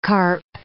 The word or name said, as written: carp